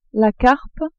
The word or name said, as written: carpe